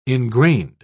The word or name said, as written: ingrained